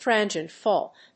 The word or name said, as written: transient fault